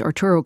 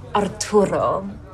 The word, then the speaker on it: Arturo: So, Arturo. – You don't have to say it like that. Arturo, you know? – Arturo.